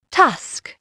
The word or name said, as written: tusk